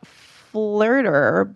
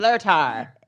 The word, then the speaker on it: Flirtar: So the app is called Flirter, but the er part is spelled with an AR. So it's like Flirtar. – Flirtar, but they say to pronounce it Flirter, but the AR is for augmented reality.